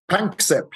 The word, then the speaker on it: Panksepp: And of course, heavily influenced by your friend and colleague Jack Pransep as well. Do I say his name right there, Mark? – Panksepp, yes.